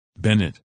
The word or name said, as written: Bennett